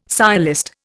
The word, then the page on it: sciolist: Indian